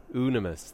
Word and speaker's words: Unimus: Ooh, Unimus. There you go. I always want to say Unimus, but it's not. It's Unimus. Unimus.